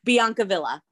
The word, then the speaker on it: Biancavilla: Help me say your last name because I always want to put a French accent on it and you're like in New York. So Biancavilla? – Biancavilla.